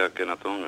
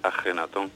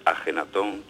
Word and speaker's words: Ajenatón: Pues ni siquiera al mencionar al rey, porque no se dice Akenatón, es Ajenatón. – Bueno, es con J, lo que pasa es que se escribe con KH por la grafía inglesa, porque los ingleses, para intentar pronunciar nuestra J, escriben KH, y de ahí ha venido lo de pronunciar nosotros Akenatón o Tutankamón y tal, pero es Ajenatón, Tutankamón.